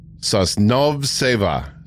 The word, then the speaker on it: Sosnovseva: Sosnovseva. – There's no shot. You said that right. I am so sorry, Victoria. – S-O-S-N-O-V-S-E-V-A. Sosnovseva. – Oh, that sounds right.